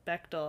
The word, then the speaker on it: Bechdel: Oh, it's Bechdel.